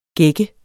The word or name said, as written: gække